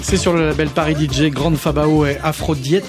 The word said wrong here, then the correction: Afro Deity: c'est comme ça qu'on le prononce ? – Afro Deity.